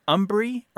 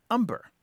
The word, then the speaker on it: hombre: And this is that there has been a long history in the United States of a very anglicized pronunciation of the Spanish word hombre, meaning man. And those two pronunciations are umbri or umber.